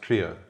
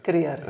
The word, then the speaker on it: Kruger: But so Kruger in Afrikaans is pronounced Kruger. – Kruger. Okay, that's right.